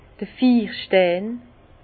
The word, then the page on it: d'r Fiirstein: Alsatian; Bas-Rhin; Herrlisheim